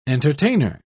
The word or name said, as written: entertainer